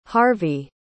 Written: harvie

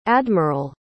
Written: admiral

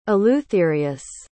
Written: eleutherius

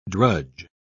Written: drudge

